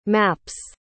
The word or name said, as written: maps